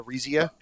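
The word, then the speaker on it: Arisia: It's Aresia, right? That's how Aresia. That's how it's pronounced.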